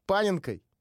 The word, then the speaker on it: Паненкой: Рамос опять забил Паненкой. Правильно я поставил ударение? Ты тут сидел, гуглил три часа сейчас. Правильно.